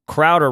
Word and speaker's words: Crowder: And with that, Steph Crowder rhymes with chowder.